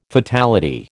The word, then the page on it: fatality: American English